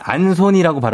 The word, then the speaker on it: 안소니: 그래서 이름이 안손이라고 발음하면 됩니까? – 네, 안손이. 안손이. 손이 손이 안손이. 손이 손이 안손이. 이게 참 붙죠? 예, 이거 들장미 네. 캔디에 나오는 그 안손이. 네, 예, 그거 안손이라고 예, 생각합니다.